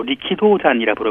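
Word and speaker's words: 리키도잔 (力道山): 역도산, 일본인들은 일본 발음으로 리키도잔이라 부르고 있는데요.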